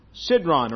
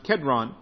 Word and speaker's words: Kedron: We are told also that it's across the Kidron Valley here in our text in John's Gospel, the brook Cidron or Kedron, and it's better pronounced Kedron, as they went across the Kedron Valley.